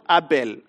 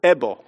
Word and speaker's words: Abel: Just to make, maybe just to highlight a few of them, I was pronouncing this word at home, Abel, and then my son was telling me, not Abel. Abel, that is Kenyan. You pronounce it Ebo.